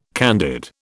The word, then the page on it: candid: British English